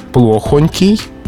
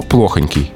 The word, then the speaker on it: плохонький: Ну а что касается произношения и э, рекомендаций для нас с вами, для тех, кто перед микрофоном, то плохонький. – плохонький. Рекомендуется в словарях для работников эфира.